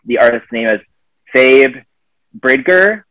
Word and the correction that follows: Phoebe Bridgers: The song is Kyoto. – Are you talking about Phoebe Bridgers? – Oh, Phoebe Bridgers. – Oh, yeah. – That's how you pronounce it.